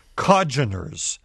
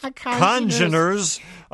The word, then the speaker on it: congeners: Well, Jim writes, I regret having to correct you, but when you were discussing hangovers, you were mispronouncing the word congeners. Coming from your upper Midwestern vocal cords, it sounded like codgeners. As in old codgers. – Like in old folks, that's right. – The N should be pronounced congeners, unless right.